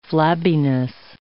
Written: flabbiness

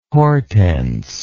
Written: hortense

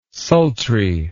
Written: sultry

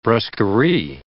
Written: brusquerie

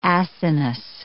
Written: acinus

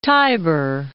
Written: Tiber

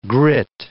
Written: grit

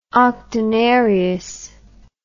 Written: octonarius

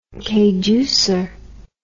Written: kayducer

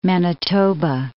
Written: Manitoba

